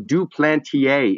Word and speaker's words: Duplantier: John Duplantier is the correct pronunciation, though I can't say correctly. I can say Duplantier correctly.